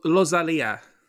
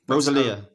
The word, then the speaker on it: Rosalía: And do you want to pronounce the title for us all? – Rosalía. – Rosalía. – That's how it.